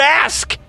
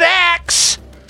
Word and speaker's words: ask: It's because the word ask is the letter A followed by S followed by K. There's only one way to pronounce it. And if you pronounce it axe, you're saying it wrong.